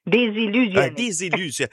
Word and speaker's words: désillusionner: Et notre sujet d'aujourd'hui pour ouvrir cette nouvelle semaine et ce nouveau mois, on va parler de délusionner. – Désillusionner. – Il me semblait qu'il manquait une syllabe là-dedans.